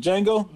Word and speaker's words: Django: Is it Django? – Mm-hmm. – I'm about to say the Django, but the J is silent. The D is silent.